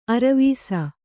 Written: ಅರ್ವಿಸು

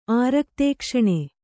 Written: ಆರಕ್ತೇಕ್ಷಣೆ

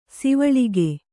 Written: ಸವಲಕ್ಕ

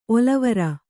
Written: ಒಲವೇರು

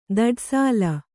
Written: ಧೂಢಸೆಲ

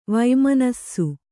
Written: ವಮನಿಸು

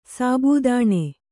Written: ಸಾಬುದಾಣ

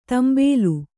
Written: ತಾಂಬೇಲು

